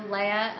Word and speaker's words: Leah: Okay, so Leia, L-E-A-H, Cohen, C-O-H-E-N, hyphen, S-A-P-I-D-A.